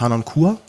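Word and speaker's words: Harnoncourt: Wie gesagt, die Aufnahme, die wir haben, ist von Nicolas Hanon-Cour.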